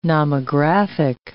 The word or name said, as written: nomographic